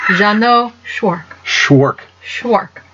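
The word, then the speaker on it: Jeannot Szwarc: Jeannot Schwark.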